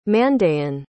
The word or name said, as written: Mandaean